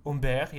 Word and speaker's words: Humbert: And is that how you pronounce his name? – Humbert, yeah.